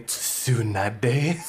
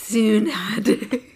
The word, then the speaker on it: Tsunade: Yeah, like some people hit the T in Tsunade so hard. – Yeah. – Tanabe. – Guys, are you mentioning Tsunade? – Tsunade.